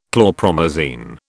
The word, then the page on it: chlorpromazine: British English